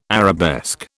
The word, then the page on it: arabesque: British English